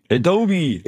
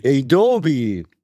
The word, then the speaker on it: Adobe: Adobe. – Adobe. Ist das richtig? Ja. Adobe spricht man das aus.